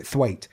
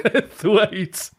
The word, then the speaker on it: Twat: it's spelt twat, but he's like Mrs. Bouquet and he calls it Thwait. So Thwait.